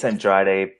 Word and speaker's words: Andrade: Do I say that right, or is it Andrade? Do I have to like? – It's Andrade, but Andrade.